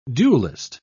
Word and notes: duellist: British English

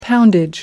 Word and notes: poundage: American English

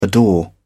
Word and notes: adore: British English

